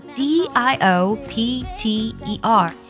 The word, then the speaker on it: diopter: 记住这个单词，它拼作 d i o p t e r，读作 diopter。 diopter 指的是屈光度的意思。平时呢，我们去做视力检验报告的时候，就能看到屈光度。如果呢是负的一点五 d，其实这里 d 呢就是 diopter 的缩写，而负的一点五呢，其实代表的就是近视一百五十度。